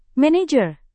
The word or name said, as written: manajer